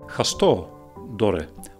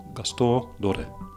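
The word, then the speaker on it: Gaston Dorren: Gaston Doren. When I say that way, I can hear my mother speak, so I suppose, yes, that would be the Limburgish pronunciation. Gaston Doren.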